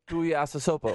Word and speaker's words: Tuiasosopo: Tuiasosopo.